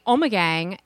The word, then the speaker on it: Ommegang: And so she was laughing at me because I pronounce Omegang, which is the traditional Belgian Dutch, they're Flemish, way of pronouncing it. And she goes, Omega what? And I said, Omegang. – It's spelled O-M-M-E-R-G-A-N-G. – No. O-M-M-E-G-A-N-G.